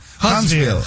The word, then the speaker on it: Huntsville: ¿Huntsville? ¿Chiri? ¿Sí lo dije bien, o no? – Sí, Huntsville.